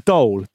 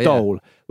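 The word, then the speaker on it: dhole: A dole. – D-H-O-L-E, a dole.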